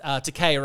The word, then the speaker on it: Takei: Cause he said once, Takei rhymes with gay. That's how you say his name.